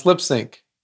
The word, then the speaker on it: Lips Inc.: One, the band is called Lips Inc. I-N-C. But it's pronounced Lipsync. Get it?